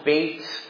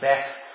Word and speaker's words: Beth: So the section this morning we're going to be looking at is, this part is called Bait in your Bible. It might look like it's pronounced Beth.